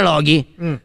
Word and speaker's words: Loki: No, non cambia, la pronuncia non cambia, è sempre Loki.